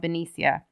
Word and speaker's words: Benicia: The double murder occurred on Lake Herman Road, just inside the city limits of Benicia. Am I saying that properly? – Yeah, I think so, yeah.